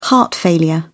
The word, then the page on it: heart failure: British English